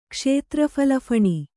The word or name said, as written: ಕ್ಷೇತ್ರಫಲಫಣಿ